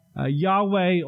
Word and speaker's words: Yahweh: We pronounce that Yahweh. Older translations were often translated as Jehovah.